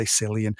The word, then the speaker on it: Cillian: His name is spelt with a C, so a lot of people struggle with that one. And it is Irish, and people say Cillian.